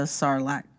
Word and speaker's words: Sarlacc: It's just unfolding his life after being eaten by the Sarlacc pit.